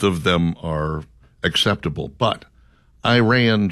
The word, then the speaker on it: Iran: I learned in broadcasting school that it was pronounced Iran, Iraq, things of that nature.